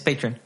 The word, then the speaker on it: Patreon: para, o pues, eh, por si lo dije mal, Patreon o Patreon. Es Patreon.